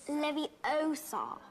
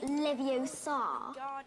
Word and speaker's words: Leviosa: Besides, you're saying it wrong. It's Leviosa, not Leviosa. Ikonisk.